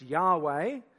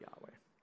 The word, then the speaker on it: YHWH: And you get the four letters, W-H-Y-W-H. Sorry, Y-H-W-H. That's God's proper name in the Hebrew sort of transliterated word. It's God's proper name, and it's probably pronounced Yahweh, but we can't be sure, and that's because those vowel points came in later, and the Jews were very